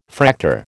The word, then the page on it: Fraktur: American English